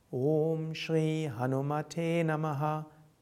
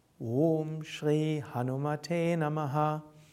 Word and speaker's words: Om Shri Hanumate Namaha: Das Mantra wird ausgesprochen Om Shri Hanumate Namaha. Om ist lang, Shri ist lang, Hanuma ist kurz, Te ist lang, Namaha kurz. Om Shri Hanumate Namaha. Om Shri Hanumate Namaha.